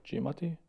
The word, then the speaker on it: Giamatti: Paul Giamatti.